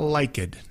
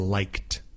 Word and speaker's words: liked: It's not liked, no, no es liked. It's liked, liked, L-I-K-E-D, but just one syllable, liked, liked.